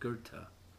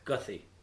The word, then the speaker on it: Goethe: That it took me years to pronounce a word called Goethe. It's spelled G-O-T-H-E, mm-hmm. Gothi. To me, that's Gothi. But it's a German language. – Well, it's called Goethe.